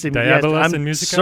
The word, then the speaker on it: Diabolus in Musica: Is it Dubious in Musica? Is that how you pronounce it? It is their Diabolus in Musica.